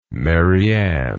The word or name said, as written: mary ann